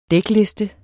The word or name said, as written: dækliste